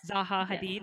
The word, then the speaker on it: Hadid: Say her name. – Zaha Hadid. Yes. Is that how you say it? Yeah.